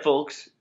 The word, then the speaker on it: folks: Just keep that in mind, folks. Keep that in mind. – Who pronounces the L in folks? – Folks. – No, it's folks. – Folks. Folks? – Folks, folks. Yeah. Like a folk singer.